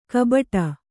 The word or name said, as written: ಕಬಟ